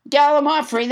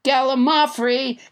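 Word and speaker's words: gallimaufry: Let's never forget Gallimafri, which I pronounced wrong until nobody, James Hyder, corrected me. Gallimafri, that's how you say it. That's it, I'm onto it now. Gallimafri, it's a noun that means confused jumble or medley of things.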